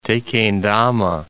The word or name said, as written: Tequendama Falls